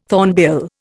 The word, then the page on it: thornbill: Indian